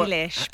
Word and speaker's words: Eilish: Is, I mean, I, I think how, Eilish.